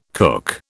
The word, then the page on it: cook: British English